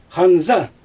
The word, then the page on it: xanza: Xyzyl